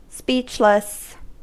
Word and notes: speechless: American English

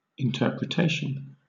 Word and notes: interpretation: British English

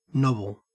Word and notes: nobble: Australian English

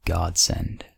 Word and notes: godsend: American English

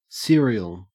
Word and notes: serial: Australian English

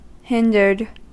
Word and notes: hindered: American English

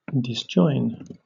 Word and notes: disjoin: British English

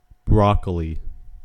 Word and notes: broccoli: American English

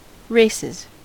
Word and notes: races: American English

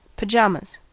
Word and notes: pajamas: American English

